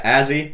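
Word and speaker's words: Azzie: So, yeah, Azzy Economo. Mm-hmm. It's A-Z-Z-I-E because no one will know how to spell it.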